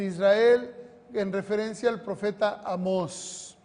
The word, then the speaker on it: Amós: No, Amós, tiene un acento en la O.